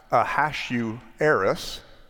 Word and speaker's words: Ahasuerus: She won what was essentially a beauty contest, having been chosen by King, and it is pronounced Ahasuerus, also known as Xerxes.